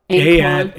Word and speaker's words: Anquan: How do you spell it? – Anquan? A-N-Q-U-A-N. – Anquan. – Sound it out.